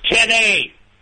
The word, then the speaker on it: Kenny: It's Kenny.